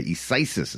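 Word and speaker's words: Isaias: Isisis. Yeah, it's a tough one to say. Isisis is how they're pronouncing it.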